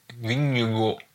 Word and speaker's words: Vingegaard: Vingegaard.